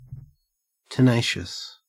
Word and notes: tenacious: Australian English

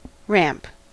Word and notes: ramp: American English

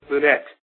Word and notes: lunette: American English

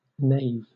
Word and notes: nave: British English

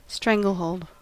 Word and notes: stranglehold: American English

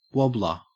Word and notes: wobbler: Australian English

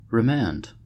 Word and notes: remand: American English

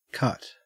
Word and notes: cut: Australian English

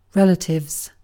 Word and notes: relatives: British English

